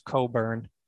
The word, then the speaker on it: Cockburn: Yeah, that's how it's spelled, and it's pronounced Coburn.